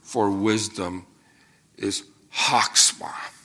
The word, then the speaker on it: chokhmah: It's one of those words that you have to start from the back of your throat to say it properly. Chokhmah. It's kind of like ruach, the Spirit of the Lord. You really have to almost just like you're clearing your throat.